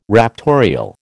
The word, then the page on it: raptorial: American English